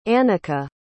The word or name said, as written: annika